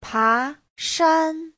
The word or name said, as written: pa2shan1